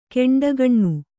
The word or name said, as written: ಕೆಂಡಗಣ್ಣ